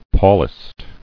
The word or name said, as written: Paulist